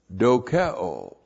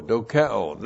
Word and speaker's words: dokeo: Now, there's another word, dokeo. D-O-K-E, long O, dokeo.